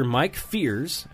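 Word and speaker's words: Fiers: So the report quoted pitcher Mike Fears, that's how you say it.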